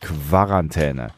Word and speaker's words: Quarantäne: Wie spricht man das Wort Quarantäne korrekt aus? Ich war bislang der Meinung, dass man es vorne wie Quelle, also Qu ausspricht. Quarantäne. Aber die meisten Leute sagen Quarantäne so, Antwort.